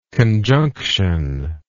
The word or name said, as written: conjunctionally